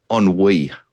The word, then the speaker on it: ennui: And I think there's a deep sense of, wait for this, ennui.